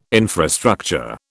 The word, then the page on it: infrastructure: British English